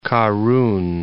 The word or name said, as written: Karun